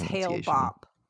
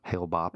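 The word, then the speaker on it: Hale-Bopp: Rumors began spreading among the group the following years that the upcoming comet Hal Bopp, I'm pretty sure I pronounced that wrong even after looking up. – I think it's Hail Bopp. – Hail Bopp. – I think it's Hail Bopp if I remember.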